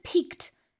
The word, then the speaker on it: pique: So this piqued his curiosity. – This is a good word here, pique, and it's spelled P-I-Q-U-E, meaning you're sort of stimulating your curiosity or your interest, and it's used as an expression like this, to pique your interest.